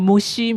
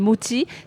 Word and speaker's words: mochi: Moti ou moti, enfin, à la prononciation à la française, j'entends souvent mochi, mais en japonais, c'est moti.